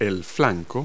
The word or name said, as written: flank